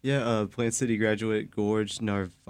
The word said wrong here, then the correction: Navarrete: I can't pronounce. Yeah, we gave you a tough one on this one. – Yeah. – I'm going to go with Jorge Navarrete. – Oh, Navarrete, yeah.